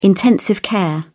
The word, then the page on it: intensive care: British English